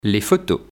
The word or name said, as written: Les photos